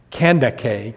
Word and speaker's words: Kandake: The name, which in our English Bibles looked like it would be Candace or Candace, probably was pronounced Kandake in ancient times, with the emphasis on the middle syllable and the hard K's in the language that was spoken back in that day and time,